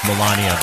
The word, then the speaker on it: Melania: It's pronounced Melania, by the way.